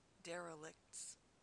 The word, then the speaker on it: derelicts: Plural, derelicts, is very difficult to pronounce correctly with a k followed by t followed by s. Derelicts, kts sound, very challenging. Most speakers automatically correct that and pronounce derelicts as if it were licks or liks. As much as you can, try to put the T in between the K and S sound.